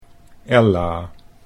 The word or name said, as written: Ella